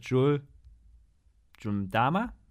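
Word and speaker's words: Jul 'Mdama: Das ist Jules Jundama. Ist das richtig ausgesprochen? Ja, ne? Mhm.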